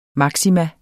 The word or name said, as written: maksimum